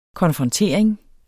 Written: konfrontering

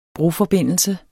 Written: broforbindelse

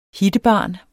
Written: hittebarn

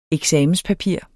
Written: eksamenspapir

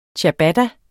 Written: Ciabatta

